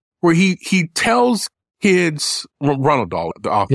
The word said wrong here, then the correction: Roald: Yeah, Roald, yeah. – What? Did I say his name wrong? What is it? – Roald Dahl, not Ronald Dahl.